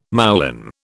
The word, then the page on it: moulin: British English